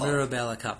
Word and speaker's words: Mirabella Cup: Mirabella Cup, that's how you say it.